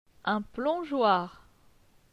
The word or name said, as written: Un plongeoir